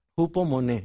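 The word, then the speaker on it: Hupomone (ὑπομονή): La palabra paciencia es una palabra griega que se pronuncia así. Hupomone, imagínense, es una palabra interesante.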